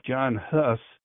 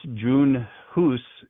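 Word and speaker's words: Jan Hus: And from there, a guy by the name of John Huss, it's pronounced, I guess, June Huss in Czech, but anyways, John Huss is more English, anglicized name.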